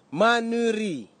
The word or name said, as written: manuri